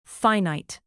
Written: finite

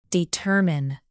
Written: determine

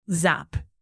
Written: zap

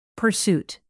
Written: pursuit

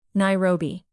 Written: nairobi

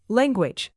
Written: language

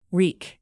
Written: wreak

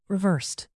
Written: reversed

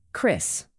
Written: chris

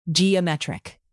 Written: geometric

